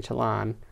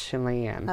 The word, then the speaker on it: Chelan: South and east to Chelan, as I've been taught. I was saying Chelan. – Chelan? – I guess it's pronounced Chelan.